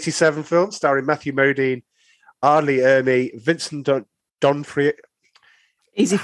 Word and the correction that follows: D'Onofrio: How is it pronounced? Is D'Onofrio. It D'Onofrio, D'Onofrio and Adam Baldwin.